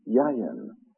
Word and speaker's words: yayin: This word wine is a Hebrew word pronounced yayin.